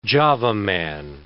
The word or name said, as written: Java man